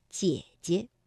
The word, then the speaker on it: 姐姐: 那么姐姐本来啊，这个赏声也应该读 214，姐。但是当连读的时候，第一个" 姐" 呢，我们就把它读成二幺，姐姐。